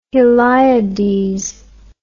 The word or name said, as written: heliades